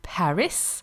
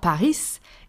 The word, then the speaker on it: Paris: So the word Paris, spelled P-A-R-I-S, is not pronounced like in English, Paris, or like it would be in French if we were to pronounce the silent letter Paris. It's not Paris, it's Paris. Paris. We do not pronounce the S at the end.